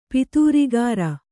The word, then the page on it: ಪತ್ರಾಗಾರ: patrāgāra